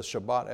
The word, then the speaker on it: Shabbat: And so what is a Shabbat elevator? Well, Shabbat is what we pronounce that as Sabbath.